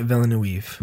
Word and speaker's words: Villeneuve: Villeneuve? – Denis Villeneuve.